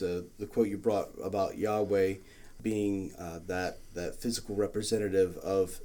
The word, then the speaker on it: Yahweh: Yeah, so the idea being that the name itself for Lord there, when you see capital, all uppercase, L-O-R-D, that's translation of Yahweh, basically. But the sound, the idea being that the name of God itself, Yahweh, when pronounced, is like the sound of breathing. So the breathe in sounds like Yah, and then the exhale sounds like way. So Yahweh.